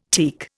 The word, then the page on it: teak: Indian